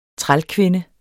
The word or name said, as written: trælkvinde